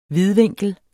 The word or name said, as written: vidvinkel